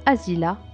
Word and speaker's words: Asilah: Nous allons commencer ce petit voyage au nord du Maroc, la ville d'Assila qui se prononce Asila par les locaux.